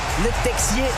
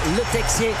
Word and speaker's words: Le Texier: Het is François Le Texier. Mijn Franstalige collega's hebben gezegd dat je de X moet uitspreken. Dus dat gaan we ook doen. François Le Texier gaat deze wedstrijd leiden.